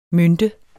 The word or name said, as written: mynte